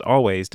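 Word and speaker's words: Alvvays: It is pronounced Always despite being spelled with two V's.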